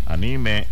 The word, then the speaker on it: anime: El término describe a alguien con un gran interés y conocimiento en temas específicos como videojuegos, cómics, ciencia ficción, anime y dispositivos electrónicos. Anime, bueno, si fuera anime, tuviera el acento en la A, no tiene acento.